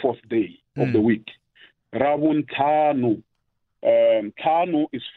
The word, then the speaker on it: Ravuntlhanu: Rabun Tanu is R A V U N T L. H-A-N-U. – Mm. – Rabuntanu. It's one word.